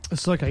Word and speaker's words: Ahsoka: And Ahsoka. Did I say it right? – Ahsoka, yes.